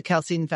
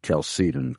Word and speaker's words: Chalcedon: Yes, I know, but I believe that those in your organization pronounce it Chalcedon, correct? – That is correct. – And not Chalcedon, and not Chalcedon or Chalcedon.